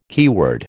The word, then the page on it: keyword: American English